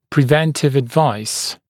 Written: preventive advice

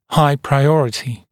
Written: high priority

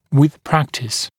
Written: with practice

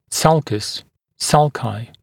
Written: sulcus мн. sulci

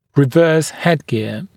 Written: reverse headgear

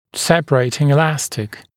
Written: separating elastic

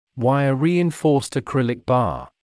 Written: wire-reinforced acrylic bar

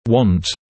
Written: want

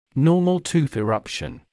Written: normal tooth eruption

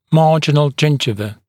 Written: marginal gingiva

